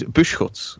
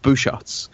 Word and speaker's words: Busschots: Okay, so we have the technical pronunciation for my surname is Buschkotz, which is the Flemish pronunciation. But we have decided as a family that no one can say that in the English-speaking world. So we have opted for the alternative pronunciation of Buschkotz.